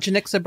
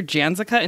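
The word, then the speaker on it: Janicza: Janixa Bravo. – Yes, I've always said her name incorrectly. I called her Janzica until someone was like, bitch, that's not her name.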